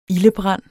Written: ildebrand

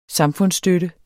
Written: samfundsstøtte

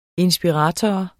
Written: inspirator

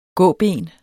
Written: gåben

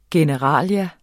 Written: generalia

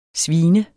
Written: svin

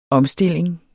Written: omstilling

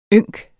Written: YNKS

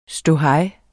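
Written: ståhej

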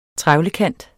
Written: trevlekant

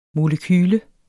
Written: molekyle

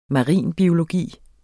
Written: marinbiologi

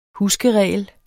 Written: huskeregel

